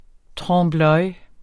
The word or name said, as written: TROMPE